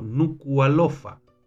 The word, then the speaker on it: Nuku'alofa: se debe pronunciar como Nuku'alofa.